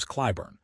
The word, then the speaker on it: Clyburn: Established lawmakers like Congressman James Clyburn, pronounced C-L-Y-Burn, argue that this longevity is a feature, not a bug.